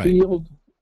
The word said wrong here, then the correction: Neild: I'm sorry, I think I totally butchered that. Um, but they were pointing out, and it's, it's really kind of shocking, really. Neild, N E I L D, Neild versus District of Columbia.